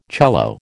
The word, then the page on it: cello: American English